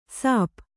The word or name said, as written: ಶಾಪ